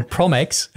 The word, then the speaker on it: PromEx: And the one really interesting thing that PromEx, I'm pronouncing it the way you're pronouncing it, Alex. It's your library, right? So you're a boss here. So PromEx,